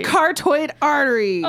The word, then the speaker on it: carotid: So, somehow as I was dictating, I turned it into cartoid. And I'm sorry, that is not how you should pronounce that word. Carotid should be spelled totally different. – I know, that's how it's pronounced. It should be C-A-R-R-O-T-E-D, not C-A-R-O-T-I-D. Not C-A-R-O-T-I-D.